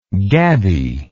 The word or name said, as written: gabbiness